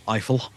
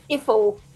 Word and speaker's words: Eiffel: I'm going to say it like trifle, Eiffel. – Iffel, by the Iffel.